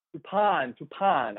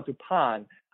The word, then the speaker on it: Toupane: Did I pronounce that right? – Toupane. – Axel Toupane.